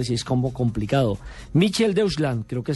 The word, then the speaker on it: Mitteldeutschland: Pues creo que se pronuncia Mitteldeutschland.